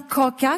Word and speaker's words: kaka: Ça s'écrit K-A-K-A, mais ça se prononce bien Koka, comme s'il y avait un accent circonflexe géant sur le A.